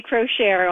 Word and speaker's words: Crochiere: Uh, the book is called Graceland, and our author, Nancy, is it Crochiere? – Yes, I say Crochiere, although I, I the family has no consensus, so you can say anything.